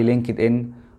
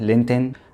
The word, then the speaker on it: LinkedIn: ازيكم عاملين ايه حابب النهارده في اول فيديو هتكلم عن ازاي الناس اللي بتتكلم صيني تستخدم الموقع اللي معظم المصريين بينطقوه غلط وانا منهم ويقولوا عليه لينكد ان وهو طبعا نطقه صحيح لينتن